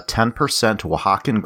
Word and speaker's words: Oaxacan: Anyway, it's 50% yellow corn, 10% Oaxacan green corn, 10% wheat, 10% rye, 10% oat, and 10% malted barley. – Great pronunciation on that Oaxacan, by the way. That's a tough word to say.